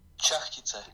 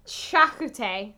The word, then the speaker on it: charcuterie: Charcuterie. – Cool. Charcuterie. So, freaking castle charcuterie.